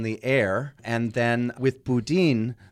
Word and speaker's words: boudin: Coming from Canada, I always want to say boudin, right. – but it's boudin, mm. the way locals say it.